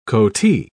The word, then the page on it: coatee: British English